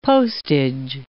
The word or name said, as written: postage